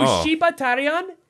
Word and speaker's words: Shibatarian: So Shibatarian is a pun on Shibata Battalion. That's the reason why it's pronounced that way. – Oh, yeah, It's a sheep battalion. Shibatarian.